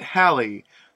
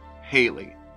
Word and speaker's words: Halley: But one pronunciation we can say pretty confidently is wrong is the one you, if you're an American, probably grew up with Haley. It doesn't seem that anyone ever said Edmund's name this way until the 1950s,